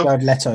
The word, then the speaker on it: Jared Leto: But Jared Leto. – Jared Leto, yeah.